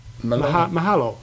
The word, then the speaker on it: Mahalo: Mahalo. – Mahalo.